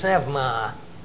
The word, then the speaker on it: pneuma: As a matter of fact, pneuma, as we would say spirit, would be said in ancient Greek, pneuma. And you pronounce the P and the N.